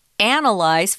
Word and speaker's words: analyze: We have the strong syllable on different uh, syllables than those. Sorry about that. Analysis, second syllable analyze first syllable.